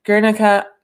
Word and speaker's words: Guernica: She is the poetry editor at Guernica Magazine. Hey, Erica, did I say that right? The magazine name? – You did.